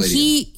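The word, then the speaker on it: Daleiden: It's D-A-L-E-I-D-E-N. – Oh, second D, okay. – Yeah. So Deladian.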